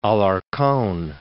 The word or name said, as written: Alarcon, de